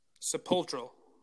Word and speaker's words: sepulchral: And it is derived sepulchral. – Sepulchral. – Yes, that is exactly it.